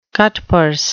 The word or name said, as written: cutpurse